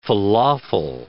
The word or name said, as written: falafel